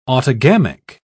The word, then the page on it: autogamic: British English